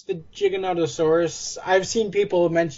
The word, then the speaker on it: Giganotosaurus: They seem to be coming to the Gigantosaurus. How do you say it? – Giganotosaurus? – That's the one, yeah.